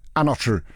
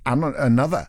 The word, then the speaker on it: Anotr: A-N-O-T-R. It's not Another. It's Anotru. Anotru. Featuring 54 Ultra. If we're saying it as it's written, Anotru. But then, you know, it's only dance music artists that do that. Go, well, I know how it's spelt, but it's pronounced Another. And you're like, well, it's not.